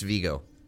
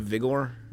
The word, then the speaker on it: Viggo: No, it's Viggo. – Are you sure? – Yes. Viggo Mortensen is how you say his name. – He's got a lot of vigor? – No.